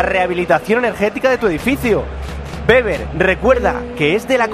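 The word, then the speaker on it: Weber: Pero el líder en confort térmico de tu vivienda tiene un nombre y se llama Beber. – Beber. Beber. – Aunque lo veáis escrito por ahí con W, que es como se escribe, se pronuncia Beber.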